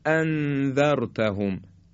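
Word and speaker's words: أأنذرتهم: به عنوان مثال وقتی ما میگیم اانذرتهم در سوره مبارکه بقره دو تا همزه داریم، وقتی میگیم ا این یک همزه است، وقتی دو تا همزه رو تلفظ میکنیم باید بگیم اانذرتهم ا اانذرتهم. حالا اگه یکی از اینا رو خوب نگیم به این شکل میشه انذرتهم، یه چیزی شبیه هم میشه انذرتهم، یا اینکه به همدیگه وز کنیم انذرتهم، باز این صحیح نیست.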